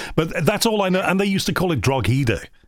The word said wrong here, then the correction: Drogheda: Drogheda, believe it or not, the G is silent. I understand why you'd want to focus in on the G, but it's silent.